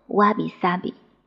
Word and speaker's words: 侘寂: 在日本侘寂这本书当中提到，侘寂读作瓦比萨比，它就是枯树、落叶、顽石。